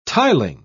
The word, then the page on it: tiling: British English